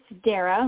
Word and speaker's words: Dara: It's Dara, like Sarah, but I answer to either.